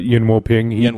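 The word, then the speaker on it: Yuen Woo-ping: But with those, he worked with Yen-Wo Ping. I'm probably butchering that name. And they did a lot of Wire stuff. – You got it. Yen-Wo Ping.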